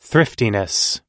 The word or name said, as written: thriftiness